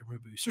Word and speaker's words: Mabuse: And Loman is like the hero who fights him in his second appearance because uh, Mabuse or Mumbu, I used to say Mabuse, it's Mabuse. I've been saying it wrong for years.